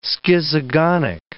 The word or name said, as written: schizogonic